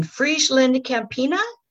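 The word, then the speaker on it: FrieslandCampina: And in his professional career, he was in marketing, sales, and business development, and was, in Friesland Campina.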